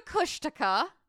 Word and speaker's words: Kushtaka: The next very strange thing that can be potentially found in the Alaska Triangle is called the Kushtika. In the first part, I called it a Kushtaka. It's a Kushtika.